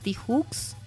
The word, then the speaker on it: Tijoux: Sí, hoy elegimos escuchar música de Ana Tijoux, así la pronunciamos, una chilena que ya les debe sonar.